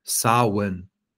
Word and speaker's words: Samhain: Aber wenn wir mal von vorne anfangen, es wird ja Samhain geschrieben, aber es wird ausgesprochen Samhain. Also man kann quasi, wenn du mal googelst und dann Samhain eingibst oder Aussprache, dann sagt er dir so Sow-Win. Samhain. Ja, so wird es ausgesprochen.